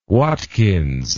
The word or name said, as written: watkins